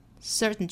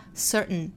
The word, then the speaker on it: certain: 这个词在美音里比较多被发成 certain. Certain 就是后面不会发一个 t 的发音了，会发 certain，直接用鼻音去代替。